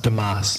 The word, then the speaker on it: Dumas: I believe it's pronounced Dumas. – Dumas. You're right. You're right. Oh. Yeah, the Quebec pronunciation.